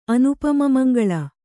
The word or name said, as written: ಅನುಪಮಮಂಗಳ